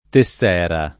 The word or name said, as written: Tessera